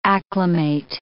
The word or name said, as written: acclimate